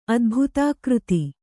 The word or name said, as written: ಅದ್ಭುತಾಕೃತಿ